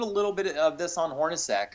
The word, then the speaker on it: Hornacek: Hornacek.